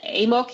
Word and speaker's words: AMOC: Die Amok oder AMOC, ich nutze hier häufig die englische Aussprache,